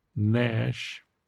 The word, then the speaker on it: gnash: Dash, dash, gash, gash, hash, hash, lash, lash, mash, mash, gnash, the G is silent, gnash, rash, rash, and sash.